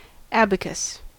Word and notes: abacus: American English

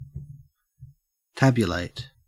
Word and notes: tabulate: Australian English

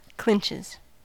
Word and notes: clinches: American English

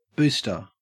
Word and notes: booster: Australian English

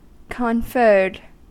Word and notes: conferred: American English

